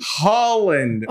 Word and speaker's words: Haaland: You're pronouncing Haaland correct. – Haaland.